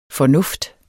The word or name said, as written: fornuft